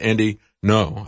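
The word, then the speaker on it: Andy Ngo: Had a journalist, Andy No, I think is the way he pronounced his name, assaulted by Antifa protesters in Portland.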